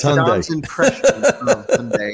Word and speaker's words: Hyundai: How do you say Monday or Sunday? – Sunday. – Yeah, there you go. You just put an H in front of it. – Hyundai.